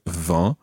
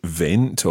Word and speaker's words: vent: Now, as we know, French spelling can be quite complicated because there's lots of silent letters in French. And if you've got the word vent, then it's quite difficult to identify how you would write that. In actual fact, it's spelled V-E-N-T. And V-E-N-T, in certain English words, might give you a clue as to what il fait du vent means.